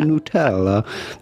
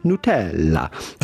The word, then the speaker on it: Nutella: So go about pronouncing this with the typical Italian pronunciation? Of course, you can always say Nutella, but with the typical Italian pronunciation, how would you say it in Italy? Well, that would be Nutella. Nutella.